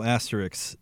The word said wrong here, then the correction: asterisk: is, did I say that right? – Asterisk. – Isk.